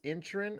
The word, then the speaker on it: Inisherin: I did get a chance to see The Fabelmans, Banshees of Inisherin, even though it's spelled Inisherin, it is Inisherin, I guess is how that's pronounced in the movie, and Knives Out Glass Onion.